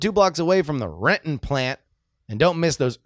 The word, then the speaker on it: Renton: Brian writes, catching up on yesterday's episode, I have lived in Renton for 6 years and it took me four to pronounce it right. You did it perfectly. That T almost disappears and the O is non-existent.